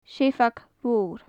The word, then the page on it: seabhag mhor: gaelic